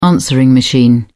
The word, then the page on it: answering machine: British English